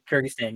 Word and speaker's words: Kyrgyzstan: Kyrgyzstan, yeah.